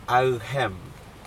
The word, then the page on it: achem: Aneityum